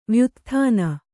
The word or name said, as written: ವ್ಯುತ್ಥಾನ